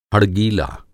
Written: हड़गीला

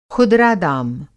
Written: खुदरा दाम

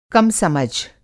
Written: कम समझ

